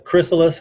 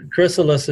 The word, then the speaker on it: Chrysalis: Wasn't that the Chrysalis House? – There you go. That, I guess that's the way you pronounce it. Yes. – Chrysalis is like the a butterfly arising from the thing.